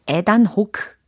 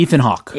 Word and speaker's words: Ethan Hawke: 여기에서도 에단 호크 영어로는 Ethan. – 에, Ethan Hawke.